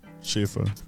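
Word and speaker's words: Schiffer: How do I say that? – Schaefer.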